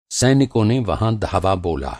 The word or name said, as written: धावा